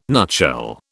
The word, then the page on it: nutshell: British English